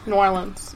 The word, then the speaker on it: New Orleans: And eventually, they were able to go outside of Texas in Esplanade Avenue in New Orleans, which I'm probably butchering the fuck out of that, because that sounds incredibly foreign. Well, you said New Orleans wrong. New Orleans.